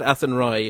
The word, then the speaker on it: Athenry: And by the way, you pronounce it Athenry if Ken Athenry.